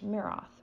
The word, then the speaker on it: Miroth: He entered the home of 38-year-old Evelyn Miroth. M-I-R-O-T-H. Miroth, right? – Yeah, that's how it's said. Miroth? – Yeah. – Miroth.